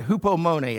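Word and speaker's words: hupomone (ὑπομονή): The Greek word translated patience is hupomone. I didn't really sound it out well there, but hupomone is how it's said.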